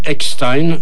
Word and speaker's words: Eckstine: Et c'est interprété par Sarah Vaughan, chanté, je veux le dire, par Sarah Vaughan et Billy Eckstein. Son nom n'est pas facile à prononcer, hein. E-C-K-S-T-I-N-E.